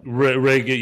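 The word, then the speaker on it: Regé: Like, Reggie Jean Page, but Reggie is R-E-G-E. – I think they pronounce it Reggae Jean Paul. – Reggae, yeah.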